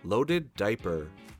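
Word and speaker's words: Löded Diper: Roderick is the drummer for the band, and Loaded Diaper is spelled L-O-D-E-D-D-I-P-E-R. The O has 2 dots above it, which is also known as an umlaut, so it's pronounced with a long O sound.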